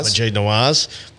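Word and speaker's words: Maajid Nawaz: Majid Nawaz,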